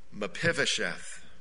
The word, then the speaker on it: Mephibosheth: And then we think of the man Mephibosheth. That's a hard word to say, four syllables.